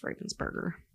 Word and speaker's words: Ravensburger: It is Ravensburger.